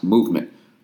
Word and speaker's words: MVMT: Oh, Movement Watches, MVMT Watches, pronounced Movement.